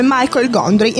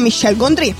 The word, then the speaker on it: Michel Gondry: io avevo pronunciato erroneamente il nome Michael Gondry. Invece il mio amatissimo tecnico Riccardo mi ha appena detto che è francese, quindi si pronuncia Michel Gondry.